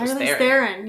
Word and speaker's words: Charlize Theron: Did I say your last name? Theron? – Theron? I was like, Charlize Theron. Yeah. – I thought it was Theron. – I think that's it.